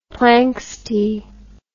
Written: planxty

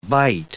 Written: bight

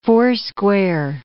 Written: foursquare

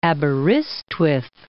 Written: Aberystwyth

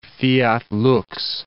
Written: fiat lux

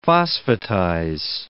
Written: phosphatize

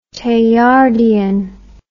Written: Teilhardian

